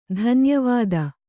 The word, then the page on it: ಧನ್ಯವಾದ: dhanya vāda